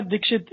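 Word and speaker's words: Dikshit: Yeah, Sheila Dixit is how we pronounce her name very honorably.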